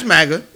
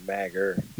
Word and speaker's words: MAGA: No, nah, it's MAGA. – MAGA. – Oh, Magger. Just making sure yeah. it's not Magger. No. they, make sure that, they, they make sure that the A in America is pronounced.